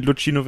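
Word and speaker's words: Luchino: der Ende der 60er von ähm, Lucino Visconti oder Lucino Visconti, er selbst hat, glaube ich, mal gesagt, dass, äh, dass, dass Lucchino Visconti Lucino Visconti ausgesprochen wird, ist auch egal.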